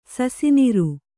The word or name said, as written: ಸಸಿನಿರು